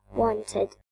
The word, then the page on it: wanted: British English